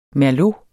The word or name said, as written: merlot